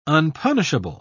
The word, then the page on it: unpunishable: British English